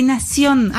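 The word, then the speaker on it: Nación: On peut vous lire dans le quotidien en norvégien Aftenposten du quotidien argentin La Nation. J'ai bien prononcé? – Oui, non, mais toujours les Français font le Z. Mais c'est Nation.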